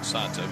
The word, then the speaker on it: Alimber: I believe he pronounces it Santa, but A-L-I-M-B-E-R.